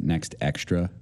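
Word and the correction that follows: NextEra: and that's not right. That's a made-up company, apparently. So, yeah, Next Era.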